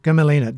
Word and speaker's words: Gmelina: So you could train a gamelina, another difficult genus to pronounce. Gmelina, G-M-E-L-I-N-A, Gmelina filipensis.